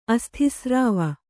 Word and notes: ಅಸ್ಥಿಸ್ರಾವ: Kannada

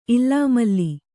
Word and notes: ಇಲ್ಲಾಮಲ್ಲಿ: illāmalli

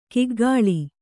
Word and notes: ಕೂಗಳಿ: Kannada

